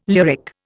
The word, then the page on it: lyric: Indian